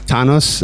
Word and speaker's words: Thanos: We were actually just talking briefly about, is it pronounced Thanos? How do you say it in German? – Thanos.